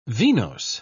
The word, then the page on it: venose: British English